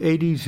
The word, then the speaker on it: Aedes: Am I saying it right? Aedes? Is that how I should say it? Yes, the Aedes aegypti.